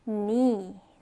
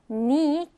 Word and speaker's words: knee: knee. 자, 따라해볼까요? knee. knee. 무릎이고요. 자, 이때 아래 보면 지금 K는 묵음으로 소리가 나지 않는다라고 하죠. knee, K로 시작을 하지만 K는 발음하지 않아요. K는 그냥 묵음으로 처리하고 knee라고 발음합니다.